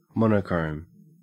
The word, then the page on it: monochrome: Australian English